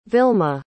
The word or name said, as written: vilma